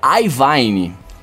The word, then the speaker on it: Iovine: E aí o Rodrigo Gonzalez tá falando que parece que o nome dele se pronuncia Ivine que foi o que ele sempre escutou e que falam no, nesse vídeo aqui, deixou o link.